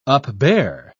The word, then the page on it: upbear: British English